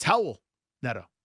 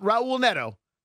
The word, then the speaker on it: Raul Neto: Despite that presence of a very firm, powerful consonant at the start of the name, that R is pronounced H. It's Howell Neto. Not Raul Neto, Howell Neto.